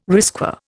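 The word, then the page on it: risqué: Indian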